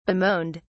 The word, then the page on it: bemoaned: British English